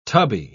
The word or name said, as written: tubby